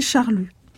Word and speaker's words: Charlus: Donc, il faut certainement, si on se la joue Guermantes, il faut certainement prononcer Charlus.